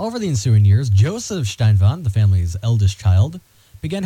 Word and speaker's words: Steinwand: Nestled among acres of farmland and the close-knit community surrounding Colby, Ambrose, now, his last name is spelled Steinwand, but that looks very German, so we're going to say Steinwand.